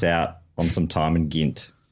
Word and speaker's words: Gent: It looks like it's pronounced Ghent to me, but... – Yeah. But I think he said Ghent Ghent.